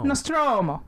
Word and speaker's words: Nostromo: The, the Nostromo. Nostromo. – Did you add a little accent in there? – No. Nostromo.